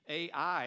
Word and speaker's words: Ai: Ai is pronounced I, like an eyeball, I.